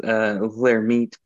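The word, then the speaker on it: Lhermitte: I'm sure I just butchered the name. test suggests a positive Lehermite test. – Yeah, I think it's pronounced Lehermite, but it's specific for cervical myelopathy.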